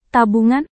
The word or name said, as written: tabungan